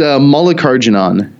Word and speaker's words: Mallikarjunan: It's Mallikarjunan. It's phonetic. It's just long.